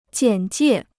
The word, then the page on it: 简介: jiǎn jiè